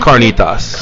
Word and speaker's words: carnitas: I had fried catinas. Am I pronouncing carnitas? – Carnitas, yep.